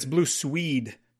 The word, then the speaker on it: Blue Swede: Like blue, you said blue suede, it's blue swede, like E E D, um, blue swede. Oh, is gotcha.